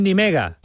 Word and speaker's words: Nimega: Tú estás en Nimega. Sí. ¿Sí? ¿Cómo se dice? Nimega lo decimos bien por aquí abajo, está bien dicho, ¿no? – Sí, bueno, yo también le digo ni mega.